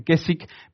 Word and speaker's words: Keswick: Y Keswick, aunque se escribe con una W, suena, se lee y se parece Keswick, se dice Keswick,